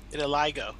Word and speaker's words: vitiligo: Vitiligo or vitilo. How do you pronounce it? – Vitiligo.